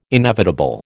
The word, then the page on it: inevitable: American English